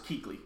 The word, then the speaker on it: Kuechly: It's just Kuechly. – Kuechly.